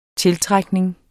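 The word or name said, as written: tiltrækning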